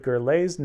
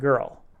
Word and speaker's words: Gurley: I was told I mispronounced Symphony founder David Gurley's name in a recent daily update. His name is pronounced Gurley and not Girl.